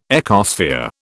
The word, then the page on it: ecosphere: British English